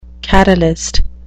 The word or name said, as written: catalyst